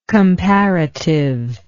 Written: comparativeness

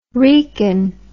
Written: wrekin

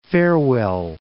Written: Farewell, Cape

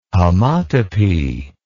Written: homotopy

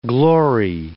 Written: glory

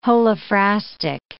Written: holophrastic

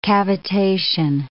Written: cavitation

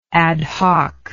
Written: ad hoc

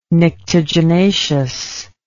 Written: nyctaginaceous

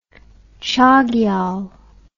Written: chogyal

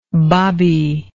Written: babite

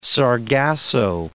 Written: sargasso

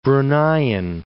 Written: Bruneian

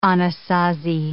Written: Anasazi

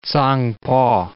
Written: Tsangpo

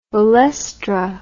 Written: olestra

